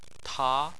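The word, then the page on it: ta: tone 2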